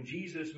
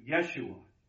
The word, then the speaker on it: Yeshua: First of all, we see that Jesus, also named Yeshua or Yeshua, he would not have responded to the English pronunciation Jesus in the crowd. He would have responded to Yeshua, because in the Aramaic and the Hebrew it would be similar, and that was what his name was.